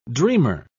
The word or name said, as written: dreamer